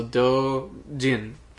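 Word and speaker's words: Doujin (同人): Well, O-U in Japanese makes a long O sound, so Doujin.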